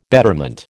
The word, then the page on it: betterment: American English